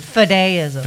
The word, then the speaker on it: Fideism: Fideism. – Fideism.